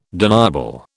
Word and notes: deniable: British English

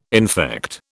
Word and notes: infect: British English

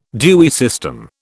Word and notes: Dewey system: British English

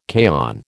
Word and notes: kaon: American English

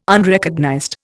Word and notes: Unrecognized: Indian

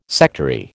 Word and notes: sectary: American English

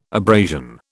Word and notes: abrasion: British English

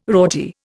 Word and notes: rorty: Indian

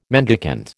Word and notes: mendicant: American English

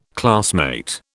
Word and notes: classmate: British English

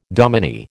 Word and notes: dominie: American English

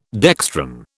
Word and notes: dextran: British English

